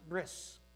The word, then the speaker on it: bris: Berith in Hebrew, the way I like to pronounce it. I tend to use the Ashkenazic pronunciation. Most people pronounce it bris, a Sephardic pronunciation.